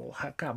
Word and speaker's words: Guajacka: Eh, no Oaxaca como se escribe, sino G-U-A J-A-C-K-A, si no mal recuerdo, Oaxaca Brand así como se escucha.